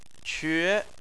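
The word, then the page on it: que: tone 2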